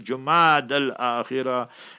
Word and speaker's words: Jumad al Akhirah: If the moon crescent is sighted, it will usher in the month of Jumad al Akhirah. That's the proper pronunciation, Jumad al Akhirah.